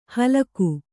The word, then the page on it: ಹಲಾಕು: Kannada